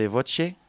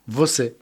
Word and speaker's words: você: Notamment cette chanson qui s'appelle « A pesar de voce ». – Vossé.